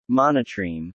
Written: Monotreme

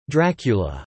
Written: Dracula

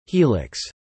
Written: helix

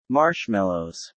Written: marshmallows

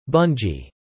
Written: bungy

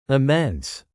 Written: immense

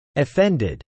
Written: offended

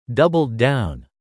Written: doubled down

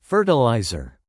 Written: fertilizer

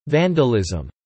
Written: vandalism

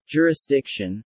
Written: jurisdiction

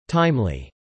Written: timely

